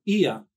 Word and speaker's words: Ea: And the very first custodian was Ea, one of the creator gods, Ea, that's how I will pronounce it.